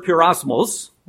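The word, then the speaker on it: pyrosmos (πειρασμός): It's the word pyrosmos, or pyrosmos is the emphasis on the last syllable, and it means to attempt.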